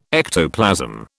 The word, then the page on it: ectoplasm: British English